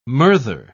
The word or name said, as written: murther